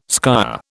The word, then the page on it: skyer: British English